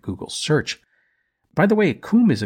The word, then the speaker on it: cwm: Now, at the end of the glacier is a great, steep-walled, semicircular basin called a coom. This specific coom was dubbed the Western Coom. A side note about this, coom is spelled C-W-M, which is pretty odd. It is Welsh and it means valley.